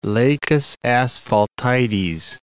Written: Lacus Asphaltites

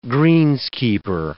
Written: greenskeeper

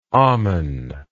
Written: amon

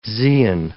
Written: zein

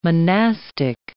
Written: monastic

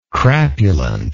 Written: crapulently

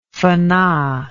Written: fana

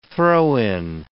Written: throw-in

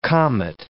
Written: comet

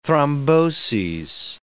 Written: thromboses

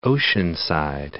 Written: Oceanside

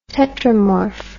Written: tetramorph

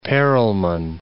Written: Perlman

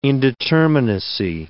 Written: indeterminacy

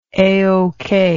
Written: A-OK

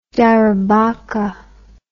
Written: darobokka

